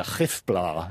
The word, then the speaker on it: Gifblaar: Now, I actually asked an Afrikaans speaker how to pronounce that, and it's a Gifblà, apparently. So. – I'll just spell it for our listeners, G-I-F-B-L-A-A-R.